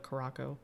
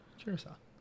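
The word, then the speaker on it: Curaçao: But I still, every time I see the word spelled out, I always want to call it Caraco. It's spelled Curacao. – Mm. Curacao. – So I always want to pronounce it Caraco.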